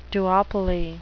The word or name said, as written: duopoly